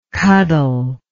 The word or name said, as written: cuddle